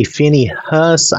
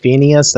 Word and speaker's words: Phinehas: Then the Lord spoke to Moses, saying, Phinehas, now you could say Phinehas, I guess, or almost Phineas. That's kind of what it looks like, but I'm pronouncing it more Phinehas.